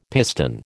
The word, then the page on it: piston: American English